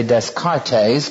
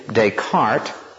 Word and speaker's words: Descartes: During the Age of Enlightenment, people like Spinoza and Leibniz, and particularly a man by the name of Descartes, or some people look how it's spelled, would say Descartes, but it's really Descartes.